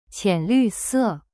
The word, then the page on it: 浅绿色: qian lu se